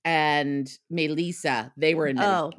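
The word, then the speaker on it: Medellín: How do you say that? – Medellin. – Medellin. Sorry.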